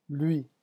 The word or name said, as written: lui